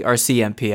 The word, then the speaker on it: RCMP: RCMP.